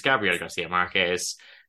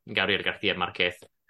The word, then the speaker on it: Gabriel García Márquez: Of course, it's Gabriel Garcia Marquez um, or Gabriel Garcia Marquez, um, if you want to pronounce it like Spain Spanish.